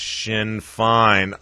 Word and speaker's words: Sinn Féin: It's Sinn Féin. Oh, but I wanted to continue to mispronounce it. Sinn Féin sounds so much better. Sinn Féin, however I was saying it. Sinn Féin. Always coming to the rescue, my great volunteer staff of research experts here on the Tyranny Response Network. Appreciate that that comment and correction. We don't want people to go away and not pronounce Sinn Féin correctly.